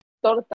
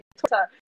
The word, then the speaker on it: torta: Torta. That's how you eat America today. Torta. – Don't say torta, you degenerate. It's torta.